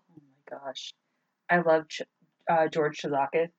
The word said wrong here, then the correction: Tsoukalos: I think that's how you say his name. – Tsoukalos? – Tsoukalos! Thank you.